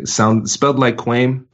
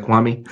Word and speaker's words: Kwame: So if you connect with me on LinkedIn, Kwame Christian, K-W-A-M-E, spelled like Kwame, pronounced like Kwame.